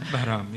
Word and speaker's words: Bahram: So one of your key characters is, do you say it, Bahram? Is that how we say his name? – Bahram, yes.